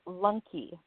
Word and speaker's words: Lunke: Is it Lunky or Lunk? How do you say your name? – It is Rainy Lunky.